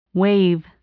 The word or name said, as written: wave